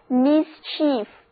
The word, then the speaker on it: mischief: 这个句子一下子把今天的三个单词串起来了。下一个单词 mischief，在 chief 前面加上 mis 的前缀，读作 mischief。